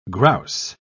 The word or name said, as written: grouse